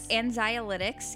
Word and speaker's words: anxiolytics: Uh, anxiolytics.